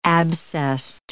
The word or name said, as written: abscessed